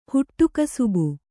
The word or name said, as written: ಹುಟ್ಟುಕಸುಬು